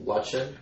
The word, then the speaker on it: Lutchen: Lutchen. A. Lutchen. – Is that how you pronounce your last name? – I think it's actually pronounced Lutchen, but most people call me Lutchen.